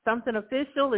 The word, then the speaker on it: Sumthn Official: Is it Sumthn Official? Is that how you say it? How are you doing? – I'm doing pretty good. That's exactly how you said it.